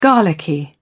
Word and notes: garlicky: British English

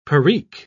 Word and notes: perique: British English

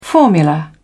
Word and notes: formula: British English

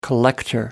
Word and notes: collector: American English

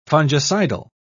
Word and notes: fungicidal: British English

